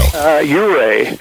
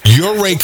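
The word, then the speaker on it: Ouray: Uray. – Uray, Colorado.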